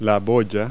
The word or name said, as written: buoy